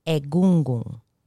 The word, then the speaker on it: Egungun: Egungun. The Yoruba, I love when they pronounce this because it's egungun. Like almost like the N kind of like gets in your throat.